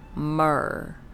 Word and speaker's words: myrrh: Myrrh. That's how you pronounce it, myrrh.